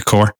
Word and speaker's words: Kaur: Valerie Coward. Yeah. K-A-U-R.